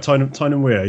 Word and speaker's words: Tyne and Wear: And this is in the county of, tell me if I'm pronouncing this right, Tyne and Wear. – Yeah, that's pretty much right. Tyne and Wear, yeah.